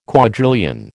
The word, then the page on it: quadrillion: American English